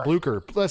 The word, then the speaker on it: Blücher: I think it's Blocher. – Frau Blücher. – Blücher. See, I couldn't remember how to say it.